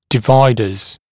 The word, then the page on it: dividers: [dɪ’vaɪdəz]; [ди’вайдэз]